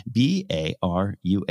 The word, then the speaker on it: Baruh: I thought it was a simple name, Baru. Barra. Have you had coffee today? Yes. Uh, no excuse. He was the director. B A R U H. How would you say that? Baru? You win.